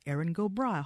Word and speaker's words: Erin Go Bragh: I do not know the meaning of Erin Go Bragh. I hope I'm saying that right. – You are saying it right.